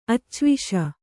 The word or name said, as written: ಅಚ್ಚವಿಷ